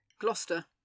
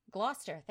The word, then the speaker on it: Gloucester: The Duke of Gloucester, how do you pronounce that? Do you say Gloucester? Gloucester. Gloucester, thank you. The Duke of Gloucester. Isn't that funny? Because over here, the C E S is always ch sound.